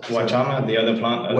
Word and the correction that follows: Huachuma: Huachuma or San Pedro.